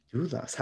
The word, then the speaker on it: Dulas: D-U-L-A-S. – Oh gosh, okay. Yes, you are saying it right if you read it in, I suppose, if you're reading it as a person who speaks English as their predominant language. But Dulas is how we say it in the Welsh language.